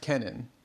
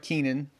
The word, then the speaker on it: Kenan: And he said that it's pronounced Kenan. And so I'm going to go with Kenan. So kind of looks like Kenan, but it's K-E-N-A-N. So it's Kenan.